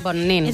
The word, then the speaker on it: Bonin: Diu que el pronunciem malament. Diu que hem de dir Bonin. – Bonin.